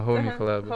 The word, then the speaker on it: homie kolabos: vis dar nežinau, kaip tikslingai ištarti, tai mane pataisyk, e, homies kolabos. – Jo, homie kolabos.